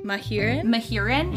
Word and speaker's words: Mahurin: But, like, it's spelled M-A-H-U-R-I-N. Shelby how do you say that? – Mahurin? – Shelby Mahurin.